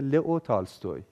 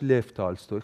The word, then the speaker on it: لف تالستوی: لئو تالستوی یا درسترش لف تالستوی خیلی تلفظ اسمشون در واقع مختلف